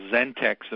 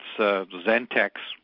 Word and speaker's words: ZenTX: The name of the product is pronounced ZenTX, a bit like Xerox with an X in front of it. So it's ZenTX.